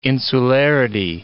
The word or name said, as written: insularity